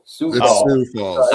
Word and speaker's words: Sioux Falls: So anyways, tell us a little bit about your current business, what you're doing with Cynics Falls Dent Repair. – So it's pronounced Sioux. – Sioux Falls.